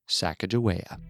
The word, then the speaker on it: Sacagawea: A quick note, the name of today's subject is pronounced many different ways. To avoid confusion, we will be referring to her by the most common pronunciation, Sacagawea.